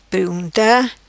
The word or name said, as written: Prigunta